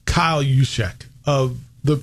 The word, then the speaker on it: Juszczyk: Juice, yeah, it's Juszczyk, but it's spelled J-U-S-Z-C-Z-Y-K.